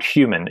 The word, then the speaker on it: HVMN: So I'd like to get then into your the company's called Human, even though it's spelled HVMN.